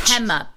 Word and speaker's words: Pema: Wait, so say it again. Hema with an H? – Pema. P-E-M-A. – Oh, okay. Pema.